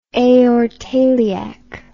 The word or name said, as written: aortoiliac